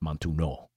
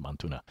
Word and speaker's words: montuno: Actually, I think it might be pronounced Montuno, but I always said it Montuna.